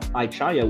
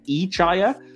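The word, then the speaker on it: I-Chaya: with regards to Aichaya, apparently Fontana, who wrote the episode, insists that we're all pronouncing it wrong. She actually wanted it to be pronounced Aichaya, but the voice actor that they hired to play young Spock pronounced it Aichaya, and the rest just kind of had to fall in line and copy it.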